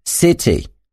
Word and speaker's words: city: Angielskie słowo city wymawia się z krótkim y, coś jak city, co wyraźnie różni się od brzmienia słowa see z długim i.